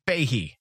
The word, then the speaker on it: Feige: Fahey? Am I going to ever learn his name? Kevin Feige. – Fahey. – Oh, I said it right.